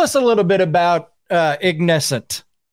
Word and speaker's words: Ignescent: Jennifer is the lead singer for the band Ignescent.